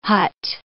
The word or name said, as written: hut